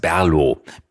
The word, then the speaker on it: BRLO: Wer schon mal in Berlin war, kennt eine Kraftbiermarke mit dem angeblich unaussprechlichen Namen BRLO. Diejenigen, die hier waren und es schon mal probiert haben, es schmeckt ausgezeichnet, wissen, es heißt Berlo. BRLO.